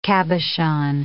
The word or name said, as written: cabochon